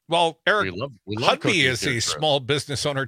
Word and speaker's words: Hovde: It's Hudby. – Hudby, yes. – I was calling him Hudby, but, you know, yeah, emphasis on the ho, like sell out, you know, Laguna Beach, sell out ho.